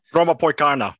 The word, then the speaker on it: Brommapojkarna: Brommapojkarna. – Yeah, that's, that. that's the yeah. one. – Poikana.